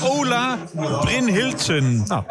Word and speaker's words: Ola Brynhildsen: Dat is Ola Brynhildsen.